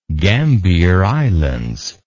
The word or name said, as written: Gambier Islands